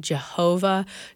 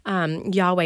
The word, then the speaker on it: Yahweh: Some people pronounce it Jehovah. So if you've heard Jehovah Shalom or Jehovah Jireh, Yahweh is a more accurate way of pronouncing it.